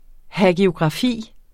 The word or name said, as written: hagiografi